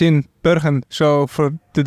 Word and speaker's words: Bergen: You're from Bergen in the Netherlands, which is... Yes. Is that how you pronounce it? – Bergen, yes.